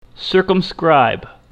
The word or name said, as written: circumscribe